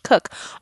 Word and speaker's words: Koch: You are correct. The correct way to pronounce my last name would be Koch, but no. It's not how we do it in the Cook family.